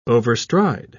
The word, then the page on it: overstride: British English